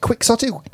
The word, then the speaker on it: quixotic: But we pronounce the adjective quixotic. – Yeah. – It's because, do you know why though? Because the Spanish speaker. – The Spanish J.